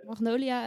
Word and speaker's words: magnolia: Ja, want ik vroeg me af, hè, want jullie hebben laatst ook een magno magnolia, hoe spreek je dat uit? – Magnolia um, ehm, ijs of, eh, siroop gemaakt.